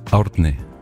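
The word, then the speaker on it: Árni: Hvad var det rigtige? Må jeg lige få den? – Ordni. – Ordni. – Ja. – Altså, jeg kender en islænding, der hedder det samme som dig. – Okay. – Jeg vil sige, det hjælper efter 4 snaps at udtale det navn. – Ordni. – Ordni. Ja. Men vi kæmper lidt med den. Arni er også okay. – Arni er også fint.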